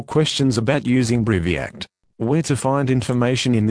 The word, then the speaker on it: Briviact: This is the consumer medicine information for Briviact, pronounced Briviact, injection for solution, active ingredient, S, Brivaracetam, Brivaracetam.